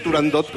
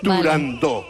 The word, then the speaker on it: Turandot: pues yo también lo digo mal y digo Turandot como todo el mundo. Pero para que quede claro... – Entonces, todos tontos ya. – Para que quede claro, es Turandot.